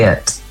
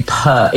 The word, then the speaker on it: Peart: Lee Peart, to rhyme with ear. Yes? – Oh, yes. Well, some people say Peart. – Do people get it wrong often? – Actually Peart. Oh, all my life. They say Peart.